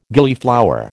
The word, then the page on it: Gillyflower: American English